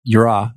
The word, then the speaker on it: yirah: Well, and it's so interesting when you say yirah, if I'm pronouncing it correctly. – Yeah.